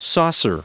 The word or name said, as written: saucer